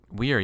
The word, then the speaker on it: Wir: Wir, yeah, it's pretty good pronunciation.